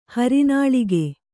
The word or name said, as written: ಹರನಾಳಿಗೆ